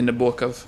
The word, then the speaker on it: Nabokov: Nabokov, you say Nabokov. – Yeah. – You can't know that unless you know Russian.